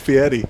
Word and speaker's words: Fieri: Don't call him Fieri. – Fieri. – That's Fieri. How he pronounces it.